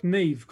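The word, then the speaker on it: Niamh: N-I-K-A-M-H. – N-I-K-A-M-H. But it's pronounced Nick. – Yeah, because it's like Neve. Because Neve in Irish is spelled N-I-A-M-H, I believe, or along those lines.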